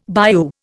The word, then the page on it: bayou: Indian